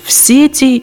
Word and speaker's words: сети: Таким образом, предпочтителен вариант с ударением на первый слог – «в сети».